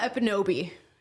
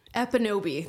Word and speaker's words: Ekpunobi: So it's Amala Ekpunobi. Ekpunobi. The K is silent in my last name for some odd reason.